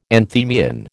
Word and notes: anthemion: American English

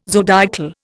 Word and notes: zodiacal: Indian

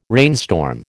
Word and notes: rainstorm: American English

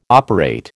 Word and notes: operate: American English

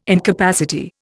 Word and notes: incapacity: Indian

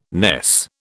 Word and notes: ness: British English

